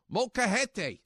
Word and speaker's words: molcajete: Let me pronounce it correctly. Molcajete. – Oh, man, you got it on point. – That's right. You use a molcajete, which basically is an Aztec.